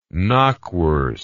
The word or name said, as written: knackwurst